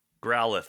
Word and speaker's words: Growlithe: Like, now forgive me if I pronounce these wrong, but, is it Growlithe? – Growlithe.